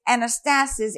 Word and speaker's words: anastasis: So the word resurrection is anastasis in the Greek language. It's pronounced Anastasis, like I just said, it is the underlying New Testament Greek word used in this verse, First Peter 1, 3.